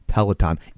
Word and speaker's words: Peloton: So, okay, we know, no need to write in, we know that it's pronounced Peloton.